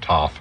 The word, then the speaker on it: Toth: It's spelled T-O-T-H. A lot of people pronounce it Toth. She pronounces it Toth, so we'll go with that.